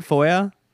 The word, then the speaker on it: FOIA: This how you say it, FOIA? – Yeah, that's right, FOIA.